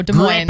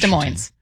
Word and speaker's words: Des Moines: Does anyone know how to properly pronounce Des Moines or Des Moines, Washington? – It's Des Moines. – I say Des Moines. – I say Des Moines. all right. Well, we were talking about it right before coming back on air. And even though we have, thank you, you are correct. You pronounce the S, says the 206.